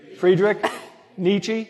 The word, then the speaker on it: Friedrich Nietzsche: How would you guys pronounce this name, Friedrich Nietzsche? – Friedrich Nietzsche. – Thank you.